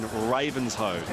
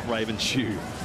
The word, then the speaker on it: Ravenshoe: By the way, thank you, Peter in Cairns, who corrects me on my pronunciation of the place in far north Queensland, Ravenshoe, not Ravenshoe. Ravens don't wear shoes, so I can imagine that would be a...